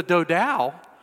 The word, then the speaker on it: Dodow: It is spelled D-O-D-O-W. All right, so I'm calling it the Dodow, all right?